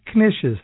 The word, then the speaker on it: knish: "Hot Knishes, Hot Knishes." That's spelled K-N-I-S-H, Knish.